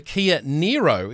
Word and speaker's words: Niro: All right, today we're going to have a chat about the Kia Niro.